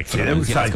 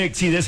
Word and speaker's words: Exide: Se depende como esto, se acuerda que ven unas baterías Exide o Exide, Exide. – Como quiera, Hormuz o Hormuz dentro. – Se escribe Exide, se pronuncia Exide, dentro decía la...